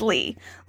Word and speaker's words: Leigh: Oh, someone corrected me and said it's Lee. Lee Whannell, even though it's spelled like Leigh, whatever.